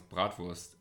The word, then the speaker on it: bratwurst: So yes, even though that you pronounce bratwurst, actually you pronounce it right, and I did it wrong on the first take.